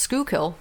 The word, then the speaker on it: Schuylkill: It's pronounced Schuylkill. – It's very Dutch word.